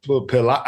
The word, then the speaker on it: Pilates: So everybody said, well, it's Pilates. I don't even know how to say it. – You kept calling it pilots, and they're like, I think it's not pilots.